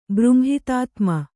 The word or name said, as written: ಬ್ರುಂಹಿತಾತ್ಮ